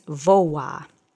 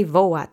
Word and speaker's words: woła: Let's say woła together three times. Accent is on the first syllable.